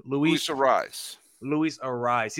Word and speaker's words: Arraez: Arias, how do you pronounce the name? Arias, is that how you say it? – Luis Arraez.